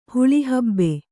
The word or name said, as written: ಹುಳಿಹಬ್ಬೆ